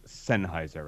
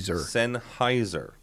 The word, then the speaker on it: Sennheiser: It is properly pronounced Sennheiser, right? – That is correct. – What am I saying? – I have a running battle with somebody I've worked with for over 20 years. Mr. Mike DeRosa, if you're listening, this is how you pronounce it, not Sennheiser. – Pronounces it Sennheiser as in the sun for 15 years.